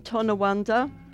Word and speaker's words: Tonawanda: Good job with Tonawanda. – Tonawanda. – I can't think of a better way to pronounce that. – Tonawanda. – I think you did great with Tonawanda.